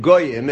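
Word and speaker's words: Goyim (גּוֹיִם): And of course, the Masor is to read it Goyim, as if it's a Vav, but it's not spelled that way.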